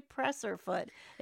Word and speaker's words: presser foot: It is a presser foot. It's how it's spelt and it's how it's said.